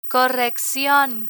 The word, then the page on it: correccion: Spanish